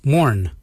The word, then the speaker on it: moorn: Yurnd is spelled Y-O-O-R-N-D. And the last colour is morn. Morn is black. Morn is spelled M-O-O-R-N.